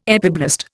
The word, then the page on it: epiblast: Indian